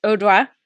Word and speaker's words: Oruo: It's then we meet uh, Odwa, who it's spelled like O R U O Odwa.